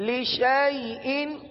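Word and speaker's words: لشيء: الف کو نہیں پڑھنا، شین کو یا کے ساتھ ملا کر پڑھنا ہے۔ اب مثال کے طور پر اگر اس کی ہجے کی جائے تو شین یا زبر شئی، شین یا زبر شئی، یوں پڑھا جائے گا، لشئین لشئین، آگے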